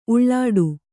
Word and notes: ಉಲ್ಟಾ: ulṭā

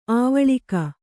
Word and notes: ಆವಳಿಕ: āvaḷika; noun

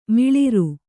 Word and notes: ಮಿಳಿರು: Kannada; miḷiru

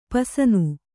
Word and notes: ಪಸನು: pasanu

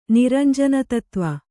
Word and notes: ನಿರಂಜನತತ್ವ: Kannada

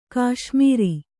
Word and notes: ಘಸ್ಮರ: Kannada

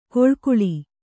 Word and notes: ಕೊಳ್ಕುಳಿ: koḷkuḷi

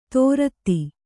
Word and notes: ದೋರ್ದಿ: Kannada

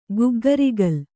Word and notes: ಗಗ್ಗರಿಗೋಲು: gaggarigōlu

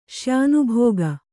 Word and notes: ಶ್ಯಾನುಭೋಗ: kannada

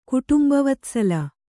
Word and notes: ಕುಟುಂಬವತ್ಸಲ: kannada

